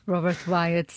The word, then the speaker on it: Robert Wyatt: Hum, uma coisa assim leve e, e, e agradável que poderia ser o Robert Wyatt. É assim que se pronuncia. – Sim, sem ser em francês, Robert o, Wyatt. É Robert é? Wyatt. – Robert Wyatt, sim.